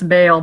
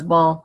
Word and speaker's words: Baal: And that is Baal, or we usually pronounce Baal, but actually the better pronunciation is Baal.